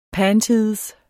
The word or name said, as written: panties